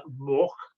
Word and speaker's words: Morck: It's called Pallet Mork, M-O-R-C-K is how we always pronounced it, and that's how we spelt it.